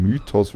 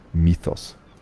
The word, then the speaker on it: Mythos: sehr verschliffene Laute und das TH funktioniert also wie im Englischen, da ist ein TH, also wird das ausgesprochen und dieses Mythos ist also kein Mythos, weil es gibt kein I, sondern das Bier heißt einfach nur Mythos.